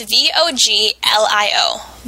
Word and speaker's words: voglio: That's V O G L I O. Voglio, I want.